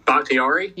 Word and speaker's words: Bakhtiari: What, Bakhtiari? – Yeah.